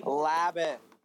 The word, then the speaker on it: Labatt: I have the correct pronunciation here. It is actually Labatt.